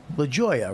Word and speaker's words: Lajoie: I have a hard time saying L-A-J-O-I-E. LaGioia, right? His name is Matthew LaGioia.